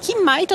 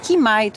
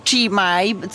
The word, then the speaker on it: chi mai: No ważne, żeby tego nie czytać chi mai, co mnie jakoś okropnie męczy, przyznam. To jest jakaś taka nasza skłonność chyba do mylenia hiszpańskiej wymowy z włoską. Po włosku zawsze tam, gdzie widzimy ch, to mamy dźwięk k.